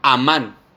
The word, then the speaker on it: amán: Dice, en su original griego se, transcribe como amán, con acento en la A, el creer.